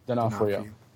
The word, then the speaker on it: D'Onofrio: D'Onofrio.